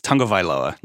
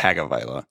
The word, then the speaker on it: Tagovailoa: I just actually was watching a video where he says it's Tagovailoa, not Tagovailoa.